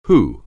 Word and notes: who: anglais

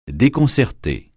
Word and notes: déconcerter: French

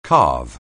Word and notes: calve: anglais

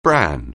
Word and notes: bran: anglais

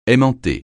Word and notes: aimanter: francais

